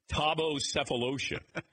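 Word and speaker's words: Thabo Sefolosha: It's like Thabo Cephalosha. Cephalosha. Thabo Cephalosha.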